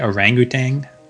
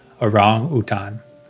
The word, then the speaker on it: orangutan: I think in English people say orangutan, but I try to go with like the pronunciation from Bahasa Indonesia, orangutan. Orangutan.